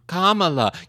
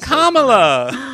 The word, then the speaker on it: Kamala: Kamala. Kamala. Kamala. You got it, Aunt Anne. Kamala.